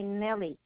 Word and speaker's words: Nellie: My first name is Irma, I-R-M-A. But you might have me only by Nelly, N-E-L-L-I-E, Galvan.